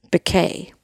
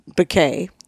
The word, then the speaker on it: Becquet: And it's spelled B-E-C-Q-U-E-T, Bickay. And her Twitter handle. – Biscuit without the S. – No. B-E-C-Q-U-E-T-S-Q is her Twitter handle. And Instagram is Mary, B as in Bickay, 3.